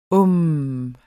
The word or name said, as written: uhm